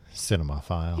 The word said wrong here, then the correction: cinephile: Yeah, it's pronounced cinephile.